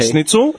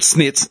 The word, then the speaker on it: schnitz: That it's not called snitz. No. That'd be fucked. What are you eating, snitz? – Yeah, but that's what I'm saying, dude.